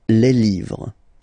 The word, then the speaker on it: livres: and then you put S at the end, but then you don't pronounce it, les livres, le livre, les livres.